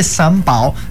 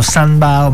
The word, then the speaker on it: Sambao: San Pao significa, questa è la pronuncia corretta, noi lo definiamo San Bao, ma effettivamente è San Pao.